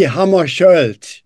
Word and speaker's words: Hammarskjöld: The writing is misleading. It's actually Hammarskjöld. You don't pronounce the K.